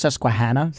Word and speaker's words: Susquehanna: It's pronounced Susquehanna.